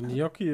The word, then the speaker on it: Gnocchi: Also Gnocchi ist richtig laut Wikipedia. Wie ist es richtig? Gnocchi. – Gnocchi. Also, dass du quasi nach dem N in J, also du, das spricht, das, also das spricht man ganz anders, als man schreibt.